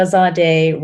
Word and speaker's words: Zadeh: Yeah, so my catchphrase in the 80s was, Misha Zadeh rhymes with Sade. Which doesn't work as well anymore, right? But back then it really helped people get it.